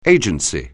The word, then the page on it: agency: anglais